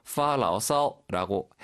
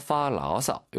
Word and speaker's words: 发牢骚: 선생님 지금 조금 전에 그 우리 제목 얘기할 때 사실은 원칙적으로 사전적인 의미의 성조는 发牢骚라고 해야 되고 뭐 그렇게 해도 됩니다. – 当然。 – 그런데 일반적으로 회화에서 发牢骚 이렇게 경성처럼 편안하게 발음하기도 합니다.